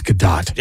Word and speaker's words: Gadot: Oh, it does say it's Gadot.